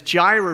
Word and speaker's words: Jireh: It's the word Jireh. That's how it's pronounced, for those of you that had questions on how to say it.